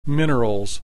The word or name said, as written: minerals